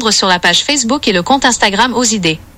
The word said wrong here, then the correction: Oxidée: Non, tu l'as très mal prononcé, c'est pas Osidée mais l'association Oxidée.